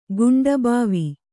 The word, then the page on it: ಕುಂಠಭಾವ: kuṇṭhabhāva